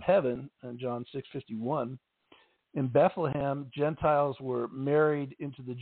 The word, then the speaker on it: Bethlehem: In Hebrew, the city's name is pronounced Bethlehem.